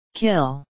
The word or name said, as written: kill